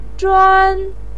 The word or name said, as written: zhuan1